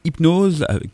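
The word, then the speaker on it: Hypno5e: Alors, on va vous passer Hypnose, qui ça s'écrit Hypno 5 et E.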